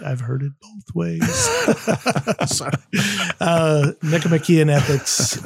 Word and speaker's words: Nicomachean: Say the word before Ethics, because I want to know how you pronounce it. – The Nicomachean Ethics. – Ooh, wow. Is okay. I mean, sure. I don't know. I say Nicomachean. – Nicomachean, Nicomachean, I've heard.